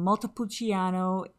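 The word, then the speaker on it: Montepulciano: And we have this really lovely red wine from Multipulciano in... – Oh, that was good pronunciation.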